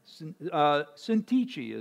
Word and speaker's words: Syntyche: Syntyche is how you pronounce it, I believe.